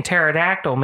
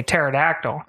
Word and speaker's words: pterodactyl: And so the silent P, which makes no sense, but the silent P in pterodactyl made me say pterodactyl. – Oh, I remember that, yeah. – So I yelled, it's pterodactyl, and then people laughed at me.